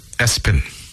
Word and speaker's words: ESPN: Somebody named their kid ESPN after the network. It's called, pronounced Espen.